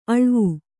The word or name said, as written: ಅಳ್ವು